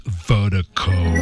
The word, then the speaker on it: Virdiko: And Vertigo is spelled V-I-R-D-I-K-O. Pronounced Vertigo.